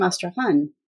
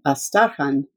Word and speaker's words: Astrakhan: Plus, she was from Astrakhan, which we all pronounce the old way, Astarkhan, and she belonged to the same clan as we did.